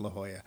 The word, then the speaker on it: La Jolla: Or is it La Jolla? – It is, in fact, La Jolla.